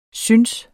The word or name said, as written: synes